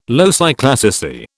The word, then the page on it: loci classici: British English